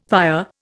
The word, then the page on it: pyre: Indian